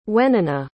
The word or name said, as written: wenona